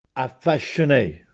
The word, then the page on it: Affascenè: Castellanetano